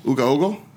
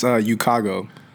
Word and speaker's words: Ukago: Nah, it's Ukago. – Oh, it's super simple. I tell people it Ukago. Rhymes... I tell people it rhymes with Chicago.